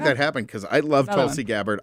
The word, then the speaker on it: Tulsi Gabbard: I'm going to butcher her name, but how do you, is it Tulsi Gabbard? – Tulsi Gabbard, yeah.